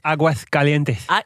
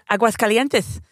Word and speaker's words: Aguascalientes: Aguascalientes. – Aguascalientes.